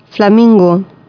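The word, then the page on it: aguapeaso: Guarani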